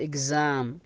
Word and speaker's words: exam: وأيضا يا أخوان يا أخوات، إذا أتى قبله وبعده حرفين علة، في أغلب الأحيان ينطق بحرف الزاء، مثل كلمات exam exam أو exit exit اللي هو المخرج.